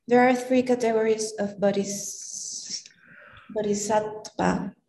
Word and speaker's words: bodhisattva: Sravaka Buddha, Pratika Buddha, and the real how do you pronounce that? – Bodhisattva. Bodhisattva. I always say bodhisattva. – Bodhisattva. – But I don't know where you get the D.